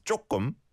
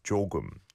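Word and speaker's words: jogum (조금): Okay, and some people pronounce this, and I used to pronounce this for the longest time as jokum or jekum. There are different ways and different accents and dialects, but the original form is jogum, jogum.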